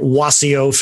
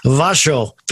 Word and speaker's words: Wasio: Now, to those who don't have the best grasp of the Polish language, uh, many would call your website Wasio Photography, spelled W A S I O Wasio Photography, but pronounced Vasho Photography. Yes, Vasho, yeah, it's Vasho.